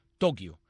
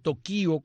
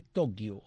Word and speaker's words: Tokio: La sílaba que llega a la fuerza de pronunciación es to, Tokio. Es una palabra llana que termina en vocal. Algunos dicen Tokio con tilde en la I, pero eso es una incorrección.